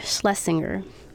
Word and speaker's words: Schlesinger: And in 1985, records were archived at the Radcliffe Institute for Advanced Study, Harvard University, in the prestigious Arthur and Elizabeth Schleisberg... you know what? Some names are not meant to be pronounced. Let's sound it out. Schlesinger. – Ooh. – Let's go with that.